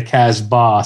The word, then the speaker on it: Casbah: Jim, as a DJ, you probably have heard this at weddings all the time, people mispronouncing it all the time, where it's the Casbah.